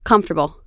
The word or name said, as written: comfortable